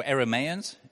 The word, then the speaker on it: Arameans: They were the Arameans, or Arameans is probably the better way to pronounce it.